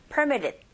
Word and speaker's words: permitted: 그리고 permitted인데, 이 ed가 지금 앞에 e라는 모음 사이에 t 사운드가 쌍으로 들어있습니다. 이런 경우에도 t 사운드 잘 안 들리고 r 소리 가깝게 들리게 되는 현상이 일어나서 permitted. 이렇게 발음하시면 돼요.